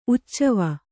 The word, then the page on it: ಉಚ್ಚವ: uccava